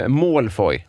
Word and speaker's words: Malfoy: Malfoy. – Ja. – Ja, det är ju tjej. Inte så långt ifrån. Malfoy, va? – Okej.